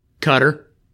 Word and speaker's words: Qatar: Cutter.